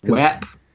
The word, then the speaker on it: WAP: WAP.